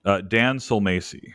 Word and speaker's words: Sulmasy: Dan Silmacy. – Silmacy. How would you spell that? – S-U-L-M-A-S-Y.